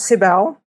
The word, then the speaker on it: Cybelle: My name is Cybelle. I'm from Brazil. You can pronounce Cybelle.